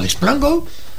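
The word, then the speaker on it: LeasePlan Go: Pues llama a Liz Plango. A ver, ¿qué son los especialistas en renting? Liz Plango. Se escribe Liase Plango. Se lee Liz Plango.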